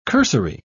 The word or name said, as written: cursory